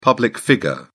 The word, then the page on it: public figure: British English